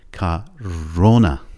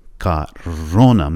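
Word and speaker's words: کارونا (корона): کارونا، کارونا، من با پام دارم ضربه میزنم روی او.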